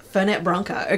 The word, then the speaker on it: Fernet Branca: Fernet Branca.